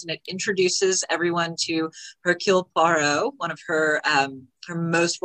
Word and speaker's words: Hercule Poirot: And of course, my Belgium reference was to Hercule Poirot. I can never pronounce it. – Hercule Poirot. – I was close.